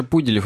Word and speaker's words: Пуделев: Кеша Пуделев, ударение на «у».